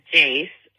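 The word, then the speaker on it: Jayce: My parents gave me this weird name, and I kind of have a lisp, and my name is Jace. It's J-A-Y-C-E, Jace. – Which is basically Joyce, but with an A.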